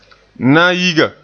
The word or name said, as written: nayiga